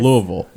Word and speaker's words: Louisville: And like, you pronounced it correctly. – Yes. – Louisville. – Yes. – Because I had a friend of mine who was from there that was like, it's not Louisville.